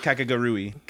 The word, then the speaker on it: Kakegurui: All right, dude, we made it through Kakegurui, or how do you say Kakegurui? – Kakegurui. – Kakegurui? – Rui. Like your girl from Domestic Girlfriend.